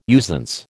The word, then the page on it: usance: American English